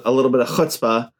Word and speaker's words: chutzpah: And do they have the cachet with the people and, you know, a little bit of chutzpah? – Yep. – Did I say that right? – You did. You said it right. You got to get a little more phlegmy with that chutzpah.